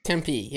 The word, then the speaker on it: Tempe: That is unfortunately how the locals pronounce it. – That's fucking wild. – How the hell else would you pronounce it? – Like in Spanish? – Tempe. – It's not Spanish. It's named after the Valley of Tempe in Greece.